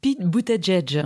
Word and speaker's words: Buttigieg: Celui dont on n'arrive toujours pas à prononcer le nom, Pete Buttigieg comment on dit, euh, Laurence Pete Buttigieg.